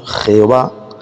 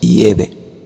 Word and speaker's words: Yahvé: Lo único que he cambiado el nombre, he sustituido el nombre falso, Jehová, por la pronunciación en hebreo arcaico, que es Yahvé.